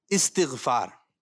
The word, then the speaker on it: استغفار: تو یہ یاد رکھیں ذہن نشین کر لیں جب بھی یہ بولا جائے گا تو یہ ہے استغفار.